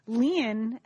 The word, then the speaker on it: Lian: No, I mean, Lian, as I spell it, L-I-A-N, is a very common Chinese name.